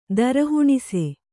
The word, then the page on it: ದರಹುಣಿಸೆ: kannada